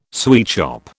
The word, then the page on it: sweetshop: British English